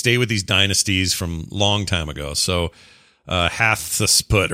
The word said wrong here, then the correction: Hatshepsut: Yeah, Hatshepsut.